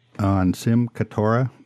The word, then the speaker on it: Simchat Torah: Our last podcast that Don and I did together was on Simchat Torah. Did I say that right, Don? – Yep, sure did.